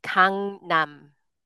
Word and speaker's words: Gangnam: And then just because you said it a few times, it's Gangnam. Gangnam for that area just south of the river.